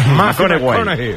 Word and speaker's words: McConaughey: ¿Matthew McConaughey? – Sí, sí, sí. Sí, sí. – Un futbolero, le gusta. Hay que ir corrigiendo la pronunciación. – McConaughey. – McConaughey.